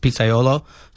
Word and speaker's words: pizzaiolo: pizzaiolo? What is that? – So a pizzaiolo is a pizza chef. In Italian, it means, it's pronounced pizzaiolo.